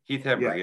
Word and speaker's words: Hembree: Am I saying that right? – Heath Hembree, yeah. Yes.